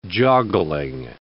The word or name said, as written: joggling